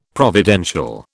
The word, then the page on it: providential: British English